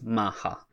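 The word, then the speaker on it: Maha (マッハ): In Japanese, it's Mach, like MACH, like the measurement of speed. – Yeah. – But in Japanese, you'll always see that pronounced Maha. Like Speed Racer in Japan is called Maha Gogo.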